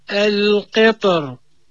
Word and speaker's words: القطر: فالساكن الذي قبلها اللي يتسبب في ترقيقها لابد أن يكون مرققا، مثل الشعر، السحر، أما إذا كان مفخما فيجب تفخيمها القطر. عاود أنطق بهذه الكلمة القطر. – القطر بارك الله فيك.